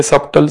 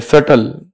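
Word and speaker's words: subtle: जैसे सूक्ष्म को इंग्लिश में कहते हैं S U B T L E. लोग इसको पढ़ते सबटल सबटल करते रहते हैं। ये सबटल नहीं है, बी साइलेंट है, सटल, B silent, सबटल world.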